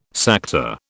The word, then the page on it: Sakta: British English